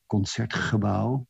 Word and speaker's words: Concertgebouw: And I had played 3 weeks before in the Royal Concert Hall in Amsterdam, which is called Concertgebouw, with this horrible Dutch G, that we pronounce like the Scots, but like the Scottish.